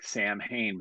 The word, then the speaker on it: Samhain: And it's spelled like Samhain, but it's pronounced Samhain.